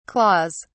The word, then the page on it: claws: British English